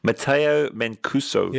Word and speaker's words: Matteo Mancuso: Matteo Mancuso.